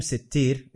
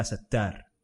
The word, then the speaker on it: Sattar: And, you know, this name Sittir is what's authentically reported from the Prophet Sallallahu Alaihi Wasallam, even though in much of the Arabic world, what's more popular is Ya Sattar. – Okay. – So they pronounce it Sattar. Ya Sattar, Ya Sattar. That's that, very popular. – Is that incorrect? Or it's incorrect.